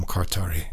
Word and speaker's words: Kartari: No, K-A-R-T-A-R-I, Kartari. – Right. – Yeah, but the first name is E-M-R-E, Emre? Emre? – Emre. – Okay. I don't know. Anyway, we'll just call him Kartari for that.